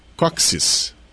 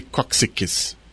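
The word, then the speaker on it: cóccix: Aí eu descobri que, na verdade, a gente pode falar só Coxis. – É, Coxis. – Não precisa repetir Coxiques. – Não. – Né? E sei lá, se a pessoa repetir também, não está errado, mas a pronúncia liberada mesmo pelos dicionários, tipo Reis ou Aurélio, é essa mesmo, Coxis.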